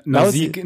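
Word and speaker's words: Nausicaä: Und einer seiner ersten Einträge so in seiner Vita ist äh, Nausicaa. Nee, jetzt habe ich schon wieder falsch gesagt. Nausika.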